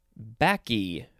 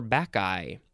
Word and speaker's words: Bacchae: Okay, we are going to move on to backy, or backeye. That's how it's pronounced. It is spelled capital B-A-C-C-H-A-E. Both of those pronunciations were good. It is a noun from 1639. One, the female attendants or priestesses of Bacchus, B-A-C-C-H-U-S.